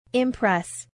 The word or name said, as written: impress